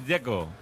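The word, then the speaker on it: Džeko: El que me parece difícil es el de Checo, Dzeko, porque DZ, ¿cómo se pronuncia? – Dzeko. Bruno dice que es Dzeko. – Como tirando a J, Dzeko o algo así.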